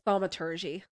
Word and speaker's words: thaumaturgy: You pronounce it as thaumaturgy.